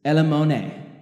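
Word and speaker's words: elemone (ἐλεήμων): This is elemone, is how you say it in the Greek.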